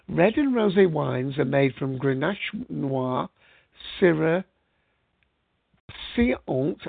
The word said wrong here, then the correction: Cinsault: I can never pronounce that, that's Cinsault, Carignan, and Mourvèdre grapes.